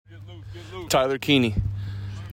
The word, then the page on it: Tyler Kiehne: TIE ler KEY KNEE